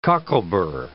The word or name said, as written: cocklebur